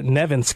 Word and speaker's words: Nevins: The one and only Jennifer Nevins, correct? Did I say your last name correct? – Hey, yeah, like right? Evans with an N.